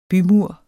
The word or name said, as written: bymur